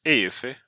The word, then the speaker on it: Eufy: E-U-F-Y. EUFY brukar jag uttala som. EUFY.